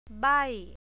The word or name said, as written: bai